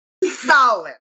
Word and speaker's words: Samhain: It's spelled, and you're gonna get there, Samhain in modern parlance, but it's pronounced Samhain.